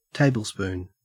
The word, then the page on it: tablespoon: Australian English